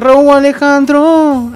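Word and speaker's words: Rauw Alejandro: Y ya sé cómo se pronuncia en la canción, lo dice, se dice Row Alejandro.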